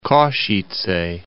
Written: Kosice

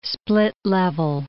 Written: split-level

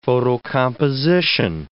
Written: photocomposition

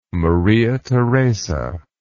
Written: Maria Theresa

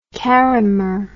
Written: karrimor

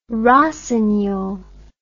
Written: rossignol

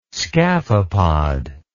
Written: scaphopod

